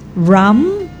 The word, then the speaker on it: Hram: Si Ram, H-R-A-M, Ram de la Torre, silent H.